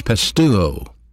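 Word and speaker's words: pisteuo (πιστεύω): The word believe is a verb, and it's pronounced in the Greek language pisteuo.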